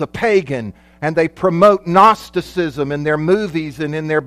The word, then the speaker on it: Gnosticism: The third major heresy that primarily the apostle John dealt with was the heresy of Gnosticism. And it's not spelled with an N, it's spelled with a G. Yeah, because it's out of a Greek word that means gnosis, which means to know.